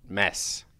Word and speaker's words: Mads: Because I was told by a Dane that you pronounce M-A-D-S as mess.